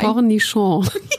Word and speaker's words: Cornichon: Cornichon.